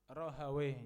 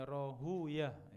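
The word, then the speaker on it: Rahawaih: Rohawih, ya, bacanya rohuyah, ya.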